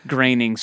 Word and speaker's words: Groening: Groening sort of guiding rules. – I think so too. Yeah. And part of that, we heard that Groening rhymes with complaining thing